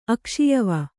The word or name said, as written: ಅಕ್ಷಿಯವ